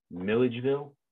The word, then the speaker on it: Milledgeville: And then the other one is Milligaville. I don't even know if I'm pronouncing that correct. – Milledgeville, yeah.